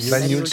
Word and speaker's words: Bagnols: Comment on le prononce? – Bagnols. – Bagnols. – Oui, ouais. C'est ça.